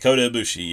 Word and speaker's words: Kota Ibushi: Kota Ibushi? – Kota Ibushi, yeah.